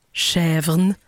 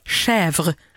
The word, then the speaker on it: chèvre: Det är någon som skriver: jag undviker aktivt att beställa chèvre, även om jag älskar den, eftersom jag har fått lära mig då att det uttalas chèvre, och jag skulle inte kunna leva med att vara så präktig.